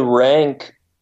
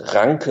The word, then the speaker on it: Ranke: Well, Rank, pronounced Ranke in German, but we can call him Rank.